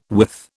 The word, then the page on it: withe: British English